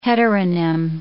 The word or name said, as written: heteronym